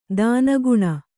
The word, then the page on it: ತೆನೆಗೊನೆ: tenegone